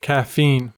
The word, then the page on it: caffeine: American English